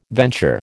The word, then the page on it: venture: American English